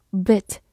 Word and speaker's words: bit: 而我们今天学习的读作 bit，字母 i 发短音 a，bit，注意不要把这两个词搞混喽。